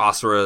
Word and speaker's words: Asura: And your character, Azura, or Asura is how they actually pronounce it in the game, or at least in the English dub.